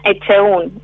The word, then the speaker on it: Echeún: Marina Echeún. Es más difícil cómo se escribe que cómo se pronuncia.